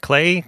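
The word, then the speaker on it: Klei: Clay? – Yep.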